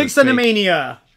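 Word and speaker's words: bixonimania: Bixanimania. I can't pronounce it because Vixenomania. – Yeah, there you go.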